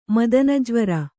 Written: ಮದನಜ್ವರ

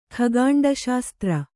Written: ಖಗಾಂಡಶಾಸ್ತ್ರ

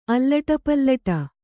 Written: ಅಲ್ಲಟಪಲ್ಲಟ

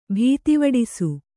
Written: ಭೀತಿವಡಿಸು

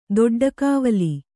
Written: ದೊಡ್ಡಕಾವಲಿ

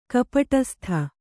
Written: ಕಪಟಸ್ಥ

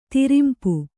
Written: ತಿರುಂಪು

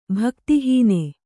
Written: ಭಕ್ತಿಹೀನೆ

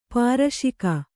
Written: ಪಾರಶಿಕ

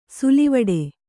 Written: ಸಲ್ಲವಿಡು

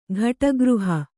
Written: ಘಟಗೃಹ

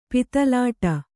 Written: ಪಿತಲಾಟ

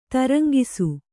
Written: ತರಂಗಿಸು

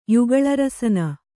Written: ಯುಗಳರಸನ

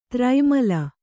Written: ದುರ್ಮಿಲ

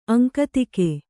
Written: ಅಂಕಿತಗೈ

